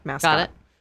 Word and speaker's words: Mascot: Furthermore, in April 2020, he was featured on the cheerleading-themed track Mascot, which is spelled M-A-S, and then in parentheses there's a K, and then O-T. Okay. So it's like mask, but mascot.